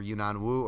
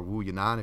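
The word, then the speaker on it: Wu Yanan: Pujalova, if you want to be proper and actually pronounce her name the right way, over Yunnan Wu, or Wu Yunnan, if you want to pronounce that name the right way.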